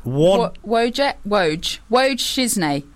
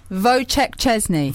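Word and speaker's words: Wojciech Szczęsny: Woj Shizne. Shizny. – Yeah, I don't think you can pronounce it any better than I can. Wojciech... – Wojciech Szczesny.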